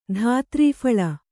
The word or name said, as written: ಧಾತ್ರೀಫಳ